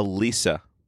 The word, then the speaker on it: Leesa: A Lisa. – Oh, how's she? – What's a Lisa? – It's a kind of mattress. It's spelled L-E-E-S-A, but yeah.